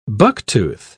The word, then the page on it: bucktooth: British English